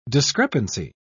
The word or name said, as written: discrepancy